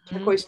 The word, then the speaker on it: turquoise: So here's rose, poem, and just below it is turquoise, which is turquoise toy oi. And the teacher was using poem, mm-hmm. and one syllable. – Right. It is like one syllable with the oi sound.